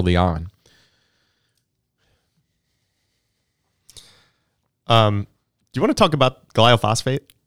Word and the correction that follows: glyphosate: I have it as gloph- Glyphosate. Glyphosate.